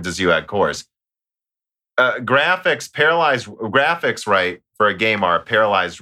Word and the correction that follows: parallelized: And it's just quicker to say paralyzed, guys. That's why sometimes I think I do. But it is parallelized. I know how to say it.